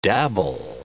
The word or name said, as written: dabble